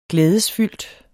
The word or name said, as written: glædesfyldt